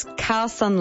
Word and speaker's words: Khaosan: It's Khaosan Lane. It's K-H-A-O-S-A-N Lane.